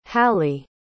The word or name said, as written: halli